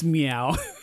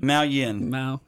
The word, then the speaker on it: Miao: Phil looked at me like, I don't think it's meow. – M-I-A-O. What do you think, Phil? – Miao Yin. – Mao, yeah. Probably just Mao.